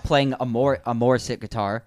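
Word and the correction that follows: Mosrite: Mazrite, that's how you pronounce it.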